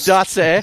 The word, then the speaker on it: Doss: Well, sucks for you because my last name is actually pronounced Dose. – Dose.